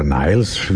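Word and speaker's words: Nilles: Jetzt haben sich die beiden verbliebenen Kanadier, der Gitarrist Alex Lifeson und Sänger und Bassist Gary Lee, mit der deutschen Schlagzeugerin Annika Niles oder Niles, wie die Amerikaner es aussprechen, und dem US-Amerikaner Lauren Gold an den Keyboard verstärkt und am 7.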